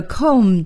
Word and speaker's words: comb: 梳子是我们在这课新学的单词 C-O-M-B comb 在这里啊，还要提醒朋友们这个 comb 的 B 是不发音的我们也叫它 silent b 所以呢要念成 comb 那么怎么来回答这句话呢 Whose comb is this?